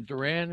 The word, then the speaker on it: Duran: Duran, is that how we pronounce his name? Jaron Duran.